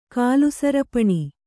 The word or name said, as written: ಕಾಲುಸರಪಣಿ